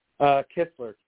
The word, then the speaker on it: Kistler: Kistler. It's K-I-S-T-L-E-R.